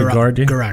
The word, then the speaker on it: Guaraldi: Guaraldi.